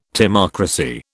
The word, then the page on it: timocracy: British English